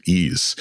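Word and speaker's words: Ys: I remember several years ago where there was an album that I was super into, Joanna Newsom Ease, that's spelled Y-S.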